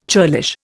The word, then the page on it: churlish: Indian